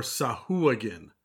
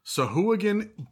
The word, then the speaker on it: Sahuagin: Officially speaking, according to actual sources like uh, Jeremy Crawford and Chris Perkins and whatnot, it's Sawajan, Sawagan, or Sahuagin. Sahuagin by far sounds the most ridiculous and bizarre to me.